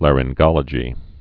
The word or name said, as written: laryngology's